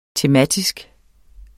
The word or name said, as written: tematisk